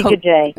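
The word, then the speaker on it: Guede: Well, after that, you mean Goide, is that how you pronounce it? The guy from Rudy Goide, yes.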